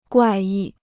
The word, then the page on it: 怪异: guài yì